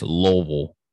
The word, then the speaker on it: Louisville: And that's how everybody says it. Louisville, Louisville. No, it's Louisville. Lol.